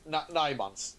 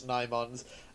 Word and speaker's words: Nimons: I never know how to say this. What about the Nymons or the Nimons or the Nymons? – Yeah, it's Nymons. You're correct, it's Nymons.